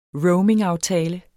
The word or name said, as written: roamingaftale